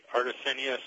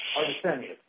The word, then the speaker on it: Artesanias: also clarify, how do you pronounce it? Is it Artesanias or Artesanias? – It's easy, Judge. This is Barry Gould. I'm counsel for Artesanias. Think of it as being arty. Artesanias, Hacienda Real S.A.